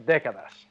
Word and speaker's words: Décadas: Just like Cinco Décadas. – Yeah. – Because the accent is on the E. I was saying it before like the accent was on the A, like Jordan did.